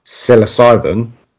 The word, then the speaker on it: psilocybin: It seems like with mushrooms, to get the psilocybin, close enough, that's it. – I'll take it. begins with a silent P, don't you know?